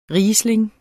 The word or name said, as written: riesling